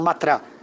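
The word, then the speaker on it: Mathura: É, os indianos chamam Matra mas é, se escreve assim matura.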